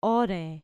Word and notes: ore: grec

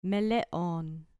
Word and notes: meleon: grec

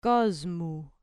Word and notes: kosmu: grec

